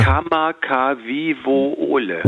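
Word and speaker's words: Kamakawiwoole (Kamakawiwoʻole): Wie spricht man den aus? – Würdest du mich jetzt so? Ich habe es jetzt gerade auch lustigerweise mir hier nochmal vorgeholt. Ja. Kamakawiwoole. – Ole, genau so.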